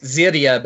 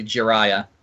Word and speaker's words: Jiraiya: Yeah, it looks like it should be pronounced Ziria, but it's actually Jiraiya.